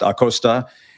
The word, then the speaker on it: Acosta: You know, it's kind of hard to shake that when your last name is Acosta, should be pronounced Acosta.